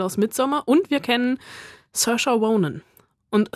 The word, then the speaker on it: Saoirse: Saoirse. – Saoirse.